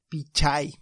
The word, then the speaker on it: Pichai: Igual Sundar Pachi, Pachai, ¿cómo se dice? Pichai, perdón.